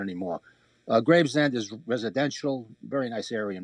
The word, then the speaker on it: Gravesend: Gravesend. – Oh, okay, That's how they say it in Brooklyn.